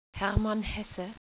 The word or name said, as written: Hesse